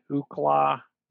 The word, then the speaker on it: Ookla: So the FCC, if you've ever used like the Ookla, mm-hmm. – that's how you pronounce it.